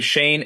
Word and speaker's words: Shayne: My name is Shane, S-H-A-Y-N-E.